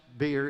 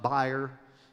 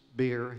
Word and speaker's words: bier: It, it is pronounced beer, it's not buyer, it's beer. He touched the bier.